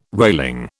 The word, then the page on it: railing: British English